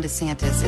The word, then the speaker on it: DeSantis: Is it a short E or long E in the last name of the Republican gubernatorial candidate? Well, his wife says... – Everyone knows my husband, Ron DeSantis, is endorsed by President Trump.